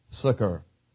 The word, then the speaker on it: succor: That's spelled S-U-C-K-E-R, different deal. Pronounced very similar, though, succor.